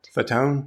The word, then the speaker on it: Fatone: Fatone.